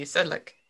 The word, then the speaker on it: Sedlec: Is it Sedlec? – Sure. – S-E-D-L-E-C, Sedlec.